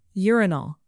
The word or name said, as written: urinal